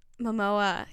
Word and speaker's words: Momoa: Momoa. Jason what? – Momoa.